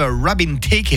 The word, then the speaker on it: Robin Thicke: On enlève Robin Thicke et Pharrell Williams du Give Me Five. Tu vois, Mickaël, c'est comme ça qu'on prononce.